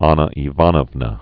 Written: Anna Ivanovna

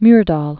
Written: Alva Myrdal

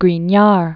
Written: Grignard

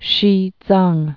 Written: Xizang

